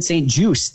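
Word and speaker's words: St. Juste: I just found out, Marcus, it's Benjamin St. Juice. The T is silent.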